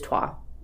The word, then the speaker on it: Troyes: So, in Black Angel, it is designed by the same designers as Troyes. – Or Trois. – It is Trois, is how you would pronounce it in French.